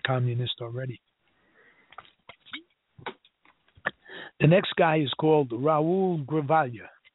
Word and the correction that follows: Grijalva: I'm probably not pronouncing that right, because I guess he is pronounced H in Spanish. Grijalva.